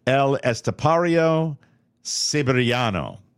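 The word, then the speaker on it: El Estepario Siberiano: So this gentleman's name, and I hope I pronounce it correctly, El Estapario Sabarinia, I'm going to try it again. God damn. El Estapario Siberiano. Siberiano. – That sounds right.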